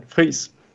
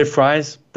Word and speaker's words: Friis: That's perfectly fine, Friis. But you can say Friis.